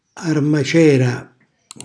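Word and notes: armacera: Calabrian

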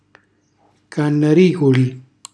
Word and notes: cannariculi: calabrese

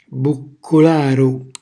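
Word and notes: bucculkaru: Calabrian

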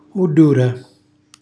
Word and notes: mullura: Calabrese

